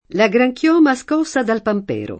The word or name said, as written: pampero